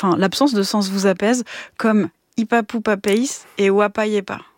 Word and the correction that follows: Wapasiepas: Wapasiepas. – Ah, pardon. Wapasiepas. – On prononce le S.